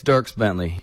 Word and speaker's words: Dierks Bentley: Hey, it's Dierks Bentley. You've been saying it right.